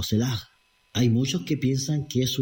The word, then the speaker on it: Selah: Se escribe S-E-L-A con una H al final. Sela, con H termina. Podría pronunciarse, digamos, si fuera en hebreo, Selah, algo así, ¿ya?